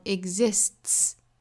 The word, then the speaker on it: exists: 好，那后面再加 s 要读成 exists，本来是 t，这里面的 t 后面加到 s 应该读 ts， exists。所以前面呢这个 s 对应的是清辅音的 s，后面 ts 组成了一个发音，就是 ts，很像我们汉语里面这个一次两次次的声母，我们发成 ts，所以这两个辅音在一起读 exists，这个词有一点不太好发，好，大家跟着我一起来读 exists， very good。